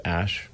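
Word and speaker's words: Æ: So, I mean, it's just X, the letter X, and then the AE is, like, pronounced Ash.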